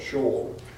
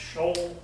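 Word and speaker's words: Sheol: S-H-E-O-U-L. Shore. – S-H-O-U-L. – S-H-E-O-U-L. Shore. It's in the scripture. It's in the Psalms. – It's outside of Jerusalem. – Yeah, but it's pronounced shoal.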